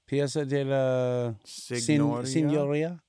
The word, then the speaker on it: Piazza della Signoria: Is two L's a Y or is it Della in Italian? – Piazza della Signoria? Signoria? – Yeah, there you go.